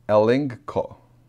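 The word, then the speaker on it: elenko (ἐλέγχω): There's the Greek number, and it's the word, as you can see pronounced there, elenko. Elenko. That's how you pronounce it.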